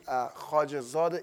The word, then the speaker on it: Khajazadeh: Last name, Khajazadeh. It's very hard for American to pronounce it, but I'm sure for Jewish people it's not that hard because you guys have a lot of che, a lot of she.